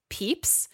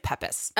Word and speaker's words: Pepys: apparently it's pronounced Peeps. – Okay. – But it's written P-E-P-Y-S. It looks like Peppis.